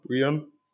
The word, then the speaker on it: Breon: B-R-E-O-N ist der Vorname, Brian.